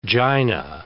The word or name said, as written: Jaina